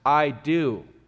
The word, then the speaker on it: eido: It's spelled E-I-D-O. It's pronounced I do.